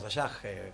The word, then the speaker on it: Ashaj: Que de hecho se escribe A-S-H-A-J, pero bueno, nosotros lo pronunciamos Ayaj por la SH, digamos.